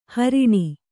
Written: ಹಾರಣಿ

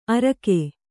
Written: ಅರೋಗ

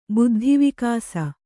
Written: ಬುದ್ಧಿವಿಕಾಸ

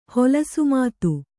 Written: ಹೊಲಸುಮಾತು

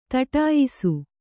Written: ಥಟ್ಟಯಿಸು

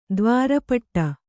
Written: ತ್ವರೆಪಡು

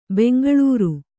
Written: ಬೆಂಗಳೂರು

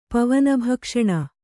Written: ಪವನಭಕ್ಷಣ

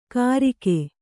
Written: ಕರಿಕೆ

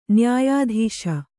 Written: ನ್ಯಾಯಾಧೀಶ

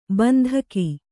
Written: ಬಂದೂಕು

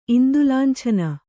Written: ಇಂದುಲಾಂಛನ

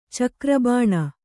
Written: ಚಿಗುರುಬೋಣಿ

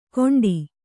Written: ಕೊಂಡಿ

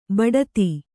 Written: ಬೊಟ್ಟೆತ್ತು

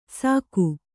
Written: ಸಾಕು

